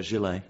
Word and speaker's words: gilet: He's clearly on a mission to bring back the 19th century French gilet. Is that how you pronounce it? – Yeah, gilet, yeah.